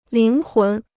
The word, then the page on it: 灵魂: líng hún